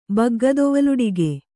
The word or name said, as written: ಬಗ್ಗದೊವಲುಡಿಗೆ